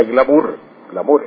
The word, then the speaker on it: glamour: Y así, glamour, que es la palabra escrita en francés, pero se pronuncia glamour, entonces así pasó al español, glamour, que se refiere al encanto, al atractivo personal, el atractivo que fascina especialmente las mujeres.